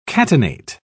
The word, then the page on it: catenate: British English